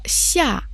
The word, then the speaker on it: 下: Слово «ся» произносится в четвертом тоне.